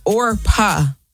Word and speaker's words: Orpah: Yeah, it's not Oprah. – No, it's Orpah.